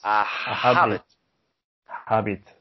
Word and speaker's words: habit: Well, as Eusebio, like Eusebio, I'm not very good with presents, but I think it's something related with an habit in our family, our parents. – A habit. – This is something from French. They don't pronounce the H.